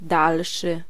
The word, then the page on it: suivant: [sɥi.vɑ̃]